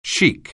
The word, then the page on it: chic: English